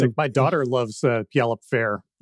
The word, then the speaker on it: Puyallup: So I think it's pronounced Puyallup. Pretty sure about that.